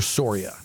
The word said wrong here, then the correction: Siora: S-I-O-R-A. – S-I-O-R-A. – Actually, Siora. It's Siora. – Yeah, mispronouncing my own name.